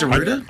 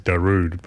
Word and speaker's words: Darude: Is it pronounced Derrida? – I always assumed it was Darude, but yeah.